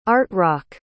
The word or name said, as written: art-rock